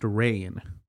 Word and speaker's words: Rian: It's spelled R-I-A-N. It's pronounced Ryan. I've also heard it pronounced Rain.